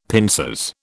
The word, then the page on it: pincers: British English